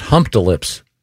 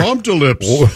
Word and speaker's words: Humptulips: I wonder if they pronounce, maybe it's pronounced Hump De Lips. – Boy, Hump De Lips is almost like, more worse. – Offensive. – Yeah. – I like it. Yeah. But yeah, it's HUMPTULIPS. Yeah, Hump De Lips sounds like a purple giraffe on a kid's TV show.